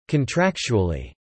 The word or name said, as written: contractually